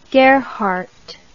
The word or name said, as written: gerhart